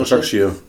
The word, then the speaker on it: Başakşehir: Und da war halt ein, ein, äh, ein, Trainer, einen Trainer aus dem Trainerstab von, von den Türken, von Bascha, ich kann das nicht aussprechen, Bascha Başakşehir.